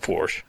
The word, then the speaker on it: Porsche: It's pronounced Porsche.